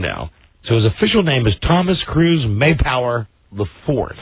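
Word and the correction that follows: Mapother: Maypower or Mayfower? – Maypother. – Is that the correct pronunciation? – Wow.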